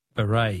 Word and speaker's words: beret: Words that end with a silent T. Beret is B-R-E-T.